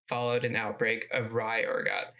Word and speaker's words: ergot: LSD, or lysergic acid diethylamide, was synthesized by Swiss chemist Albert Hofmann in 1938 from lysergic acid derived by processing ergot. It's ergot. Ergot? – How do you spell it? – E-R-G-O-T. – Ergot, yeah.